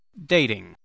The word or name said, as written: dating